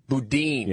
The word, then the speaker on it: Boudin: Boudin. – Yeah. – No, not Budin. Yeah, Boudin.